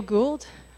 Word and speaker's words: Gould: Rachel Gould, R-A-C-H-E-L-G-O-U-L-D.